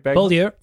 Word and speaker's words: Beaulieu: Jets again acquire Nathan Beaulieu. Did I pronounce that right? – Beaulieu. Yep. – Beaulieu, thank you.